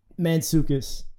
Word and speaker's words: Mantzoukas: How do you say his name? – Mantzoukas. So Mantzoukas. – Is that the Greek? – Yeah.